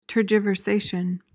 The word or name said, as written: tergiversation